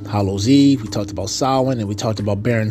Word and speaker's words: Samhain: And I was one of those people one time ago where I used to say that, but it's actually pronounced Samhain. And I pronounced it that way last year, Samhain, because that's its actual way to say it.